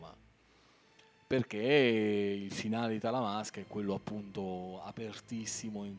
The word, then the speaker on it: Talamasca: Talamasca, giusto? Ho detto bene? – Talamasca, sì, sì.